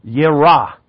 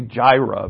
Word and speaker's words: Yireh: So I'm Jehovah Yireh, or we say Jireh, but it's a wrong pronunciation.